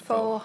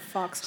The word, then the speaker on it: faux: Yeah, it's not fox. – Sorry, hawk. I did. I'm pretty sure it was faux hawk, but then people would spell it that way, and I was insecure about how I was saying it, so I stopped saying it. – That's okay. It's faux.